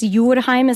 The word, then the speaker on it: Jordheim: Jordheim is my last name, how it's actually pronounced, means earth home.